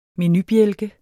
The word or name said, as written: menubjælke